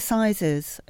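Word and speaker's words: assize: And one of the first, is it pronounced the aziz of bread? – I think it's the sizes of bread.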